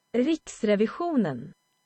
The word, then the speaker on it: Riksrevisionen: But in Swedish, it is Riksrevisionen. – Riksrevisionen. So, yeah, I obviously Englished it up.